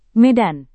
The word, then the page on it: medan: Indonesian